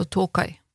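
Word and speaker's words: Tōkai: Og den landsbyen, eller det området vi skal til, som altså heter Tokai Jeg vet ikke om jeg uttaler det riktig, en O med en strek over. Jeg vet ikke om den uttales annerledes, men Ja, det vil vel som regel bare bety at det er trykk på den O-en. Tokai. Altså Tokai.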